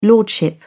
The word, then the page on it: lordship: British English